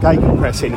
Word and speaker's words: Gegenpressing: How do you say it? Gegenpress or Gegenpress? – Gegenpressing.